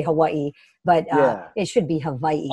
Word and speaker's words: Hawaii: No, well, so according to people who live on the mainland, we say it, the local people say it like somebody's punching us in the gut. So we say Hawaii. So it's Hawaii. Always like Hawaii.